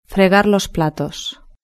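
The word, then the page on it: fregar los platos: European Spanish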